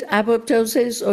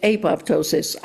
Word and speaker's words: apoptosis: Okay, you can pronounce it apoptosis or you can pronounce it apoptosis. Either way, is medical, is scientific, it's accepted.